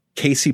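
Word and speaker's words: Cayce: Even the central conceit of Casey Pollard.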